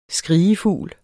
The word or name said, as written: skrigefugl